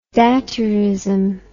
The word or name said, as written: daturism